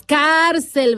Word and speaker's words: cárcel: Ahora sí que, ¿cuál es la sílaba fuerte? ¿Cárcel o cárcel? Ah, pues cárcel, ¿verdad? Es una palabra grave. Su sílaba fuerte o tónica es la penúltima sílaba.